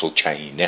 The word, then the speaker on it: سکینه: ایشون دختر دیگری هم از امام حسین دارن به نام سکینه، که تلفظ عربی درستش سکینه هست، که در فاجعه کربلا به اسارت در میان.